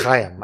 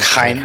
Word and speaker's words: Chaim: I think it's Chaim. I think it's in Chaim. Yeah, I know.